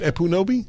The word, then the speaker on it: Ekpunobi: Will Witt and Amala Ekpunobi. – By the way, the K is not pronounced. – Oh, really? It's just Ekpunobi? – Yeah. – Oh, cool. So it's like K in knowledge.